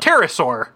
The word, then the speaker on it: pterosaur: Because when you have a P before the T, the P is silent. Like pterosaur.